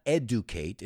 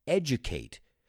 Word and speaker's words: educate: It's not educate, it's edge, like el borde, educate.